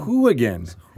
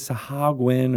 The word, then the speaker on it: Sahuagin: I hear you're going to finally definitively pronounce Sahuagin correctly. – Sahuagin. – Sahuagin. – Yeah, exactly. I always thought it was Sahagwin or Sagwin. But you pronounce the oo, which I'm very excited to have you guys.